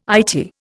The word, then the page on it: Eyetie: Indian